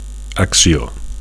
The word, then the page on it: accio: Catalan